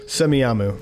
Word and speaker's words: Semiahmoo: Cascadia Fest, August 2nd through 5th in Washington State in semi Someone tell me how to pronounce that. I always, I, I'm not even looking at it. I don't even know how to spell it. Semiahmoo. Yeah. All right. Thanks. J- I, gr- I grew up near it, so I know how to say it.